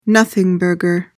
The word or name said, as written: nothingburger